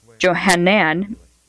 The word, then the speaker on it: Johanan: The name John, pronounced Johanan in Hebrew, means Yahweh is merciful, the name of John's father.